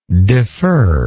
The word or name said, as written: deferrer